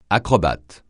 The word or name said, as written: acrobate